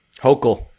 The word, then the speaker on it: Hochul: Did I pronounce her name right or wrong? – Hochul.